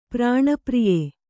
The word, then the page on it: ಪ್ರಾಣಪ್ರಿಯೆ: prāṇa priye